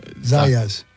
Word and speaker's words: Zayas: Zayas.